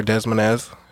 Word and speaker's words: Des Moines: But yeah, they're from Des Moines, Iowa, or Des Moines. That's how they say it.